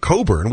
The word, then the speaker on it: Cockburn: Now, some people have that name and pronounce it Coburn,